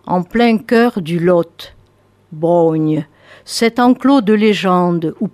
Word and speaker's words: Braunhie: La Brogne, alors que ça s'écrit B-R-A-U-N-H-I-E, la Brogne, dites-vous. Nom hirsute et bizarre au premier regard, mais familier à tous les Quercinois. On le prononce Brogne, et se lèvent alors des chaînes sans fin, tenaces, tordues, une dense forêt venue tout droit d'avant, même l'histoire.